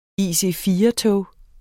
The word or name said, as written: IC4-tog